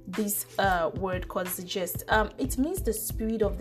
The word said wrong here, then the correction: Zeitgeist: So this is how it's pronounced on Google. – Zeitgeist. – Okay, so it's called Zeitgeist. It's the spirit of the age.